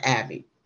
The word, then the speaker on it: Aebi: He spells his last name A-E-B-I, but it's pronounced Abbey.